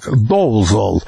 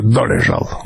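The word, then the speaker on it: Dolezal: Кстати, имя, которое американцы произносят здесь как Долзол, это, несомненно, чешское Долежал.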